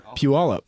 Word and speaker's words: Puyallup: Puyallup? – Is it really Puyallup? – Puyallup. P-Y-U-A-L-L-U-P, I believe.